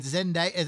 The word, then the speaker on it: Zendaya: Zendaya. That's how you pronounce it? – Yeah. – I always wondered how you pronounce it. – Z-E-N-D-A-Y-A.